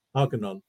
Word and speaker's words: Argonon: What's the backstory of Argonon, and how do you pronounce it properly so that we got it down? – Argonon.